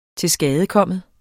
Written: tilskadekommen

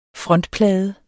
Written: frontplade

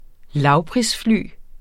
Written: lavprisfly

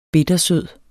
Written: bittersød

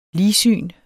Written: ligsyn

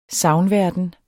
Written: sagnverden

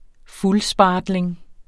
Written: fuldspartling

